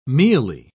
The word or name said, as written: mealy